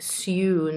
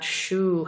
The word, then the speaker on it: Sue: By the way, it's Sue, not shoe, how some people, students pronounce it.